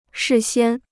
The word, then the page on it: 事先: shì xiān